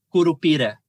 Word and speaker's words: Curupira: Now, I might butcher the pronunciation, but is it Curupira? – Curupira. – Curupira? – Yes.